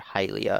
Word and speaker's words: Hylia: Hylia, I guess Tucker said is how it's pronounced.